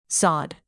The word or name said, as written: sod